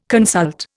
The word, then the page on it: consult: Indian